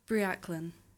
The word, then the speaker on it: Briellan: Briaclan. – Okay, and that's it's not an English name, is it? – No, it's Welsh.